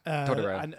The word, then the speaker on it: Totoro: Well, I've never seen My Neighbor Totoro, which I know is like, is that how you say it? – Yeah. – Totoro.